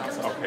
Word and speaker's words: 감사합니다: 감사합니다.